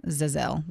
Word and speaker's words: Za'Zell: So first, let me tell you about the shining bright light that is Zazelle Preston. I believe I'm saying her name right. It's Z-A-apostrophe-Z-E-L-L. Zazelle, wouldn't you say? – Yeah.